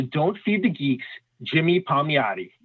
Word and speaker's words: Palmiotti: Yeah, Palmiotti.